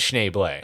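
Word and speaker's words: Schnebly: This comes from the real Ned Schneebly. – Actually, it's pronounced Schneeble.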